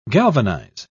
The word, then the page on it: galvanise: British English